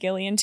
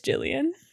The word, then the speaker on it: Gillian: I think it's Jillian. I have always said Gillian too, but I just looked up how you pronounce her name and I just don't want to sound stupid.